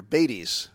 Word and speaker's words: Beytes: Really close, Peter. Bates. – Bates. It's spelled B-E-Y-T-E-S.